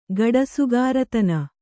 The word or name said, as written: ಗಡಸುಗಾರತನ